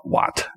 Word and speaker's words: Wat: A Buddha would call it Wat. That's how you say it in Thai, like a Buddhist temple.